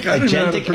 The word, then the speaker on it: agentic: You're very agnetic. – Yeah, exactly. I'm getting there. Agentic. Yeah. All right. – And also, remember this. – Is that how you say it? Agentic? – Agentic AI.